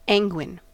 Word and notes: anguine: American English

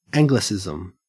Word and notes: anglicism: Australian English

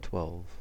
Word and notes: twelve: American English

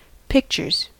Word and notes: pictures: American English

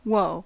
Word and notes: whoa: American English

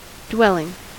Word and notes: dwelling: American English

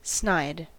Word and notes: snide: American English

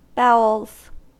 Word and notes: bowels: American English

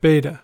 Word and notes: beta: American English

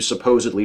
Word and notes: supposedly: American English

